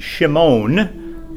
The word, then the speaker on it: Shimon: So his name is derived from that word, meaning to hear, and it's actually pronounced Shimon, which we transliterate into something we would understand, meaning Simeon in English language.